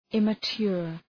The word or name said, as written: immature